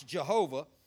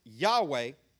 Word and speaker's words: Yahweh: Now, this word that we pronounce Jehovah, is the same word that is pronounced Yahweh.